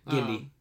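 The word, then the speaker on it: Genndy: Yeah, Gindy.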